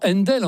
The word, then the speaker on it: Handel: Händel, H-A-N-D-E-L, ça se prononce tout naturellement Händel en anglais.